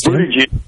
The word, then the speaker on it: Buttigieg: But when I look at the folks immediately behind him, Bernie Sanders, Pocahontas, Buttigieg, how do you say his name? – Yes, Buttigieg. – Nice job.